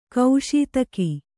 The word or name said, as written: ಗಸಿತೆಗೆ